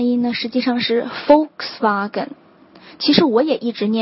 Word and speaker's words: Volkswagen: 因为德语的这个 V 的这个音呢，在英文当中应该是发成这个字母 F 的这个音的，所以正确的发音哈，真正懂车的人的发音应该是 Volkswagen，第一音节就是 Volks 这个单词的发音。